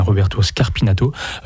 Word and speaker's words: Roberto Scarpinato: Et ce dont témoigne Roberto Scarpinato, parce que je crois que j'ai écorché son nom en fait en début d'émission, donc c'est bien Roberto Scarpinato.